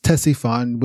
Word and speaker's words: Ctesiphon: The capital is known as Ctesiphon, which is spelt C-T-E-S-I-P-H-O-N.